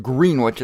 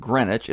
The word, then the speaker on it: Greenwich: Yes, it's pronounced Greenwich. It's not Greenwich.